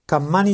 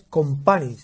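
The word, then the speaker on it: Kompany: Has dicho dos veces Campani y es Compani.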